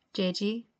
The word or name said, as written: Jeji